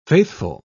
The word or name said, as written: faithful